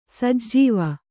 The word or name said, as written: ಸಜ್ಜೀವ